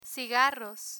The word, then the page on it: cigarros: spanish